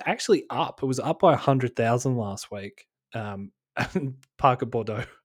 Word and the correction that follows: Boudreaux: or Bordeaux? How I keep saying it incorrectly. Parker Boudreaux.